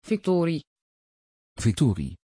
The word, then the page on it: viktorie: Dutch